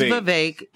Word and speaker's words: Vivek: First of all, it is Vivek, and it rhymes with cake. And that is how you will always know how to pronounce that man's name.